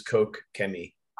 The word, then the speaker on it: Koch-Chemie: Everybody says Koch-Chemie. So whatever flows off the tongue, but yeah. the actual pronunciation is Koch-Chemie.